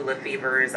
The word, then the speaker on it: Lefebvre: The B is pronounced, but it's normal. It's D-A-N and L-E-F-E-B-V-R-E.